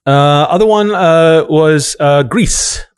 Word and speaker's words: Gris: Well, it's the French word for gray, oh, okay. – Which can be pronounced gris. But also grease, like oil. – Yeah. – Anyway, that's how the developers pronounce it.